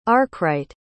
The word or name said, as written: arkwright